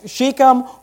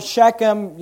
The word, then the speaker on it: Shechem: So he's in this location known as Shechem or Shechem, different people pronounce it different ways, which is the land of Canaan.